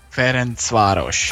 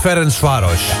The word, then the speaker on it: Ferencváros: En dat spreek je dan weer zo uit. – Ferencváros. – Ah ja. Ferencváros.